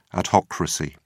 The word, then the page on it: adhocracy: British English